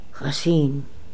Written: Hassein

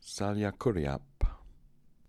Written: Saliakooriap

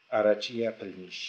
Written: Arachiyaplmish

